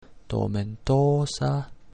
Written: tomentosa

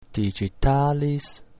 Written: digitalis

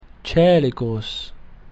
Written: coelicus

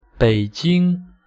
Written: Beijing

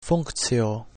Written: functio

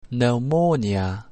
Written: pneumonia

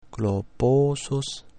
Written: globosus

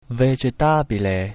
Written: vegetabile

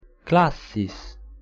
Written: classis

